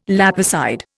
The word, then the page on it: lapicide: Indian